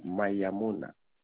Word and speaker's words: Mayamona: Monsieur Mayamona, ça s'écrit MAYAMONA.